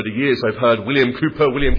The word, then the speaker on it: Cowper: I'm going to pronounce him William Cowper. I know that for some pronunciation is Cowper, and you see a cow when you pronounce it as a cow,